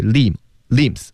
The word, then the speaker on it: limbs: 四肢那个词呢，limbs 那个 b 不发音，就是 limbs，